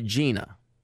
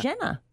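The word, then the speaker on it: Jena: Like that girl on American Idol this season, her name is spelled Jenna. But she goes by Gina.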